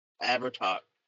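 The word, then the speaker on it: Abhartach: I think it's pronounced Abhartok, because I don't think you pronounce the H.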